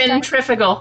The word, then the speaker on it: centrifugal: One more centrifugal. – Time? Centrifugal.